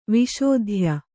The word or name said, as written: ವಿಶೋಧ್ಯ